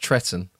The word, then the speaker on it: Trenton: So where he's from, I know it's spelled Trenton, but it's actually pronounced Tretton.